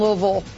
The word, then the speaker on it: Louisville: You gotta say it right. Louisville. – Louisville. I've been practicing. You can tell I got the peanut butter in my mouth. Louisville. That's how they like it said.